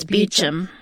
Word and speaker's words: Beecham: Like Toby Meacham, but it's Beecham.